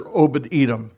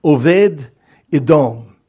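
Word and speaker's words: Oved Edom (עבד אדום): No, his name is pronounced in Hebrew, Oved Edom. But the way that it's spelled there, the second part, Edom, it's Aleph Dalet Mem, or Mem Sufit, as it's written in your handout. It can, without the vowel sounds there, and is in modern Hebrew, it could be pronounced Adam.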